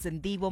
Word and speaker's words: Ndivho: My name is Ndivho Makwanya and it's spelled out as N-D-I-V-H-O.